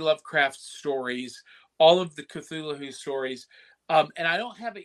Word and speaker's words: Cthulhu: Or as I've been told by George Norrie, who hosts Coast to Coast AM, that is actually pronounced Cthulhu. – Oh, you actually pronounce it with the H. Interesting. Yeah. I thought that was a silent H.